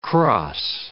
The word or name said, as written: cross